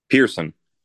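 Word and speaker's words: Pierson: Pearson. – It's just Pearson? – Just Pearson. – It's spelled just weird? – Yeah.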